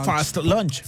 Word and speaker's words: Fast Lunch: Así se escribe, Fast Lunch. – Bien pronunciado, Fast Lunch.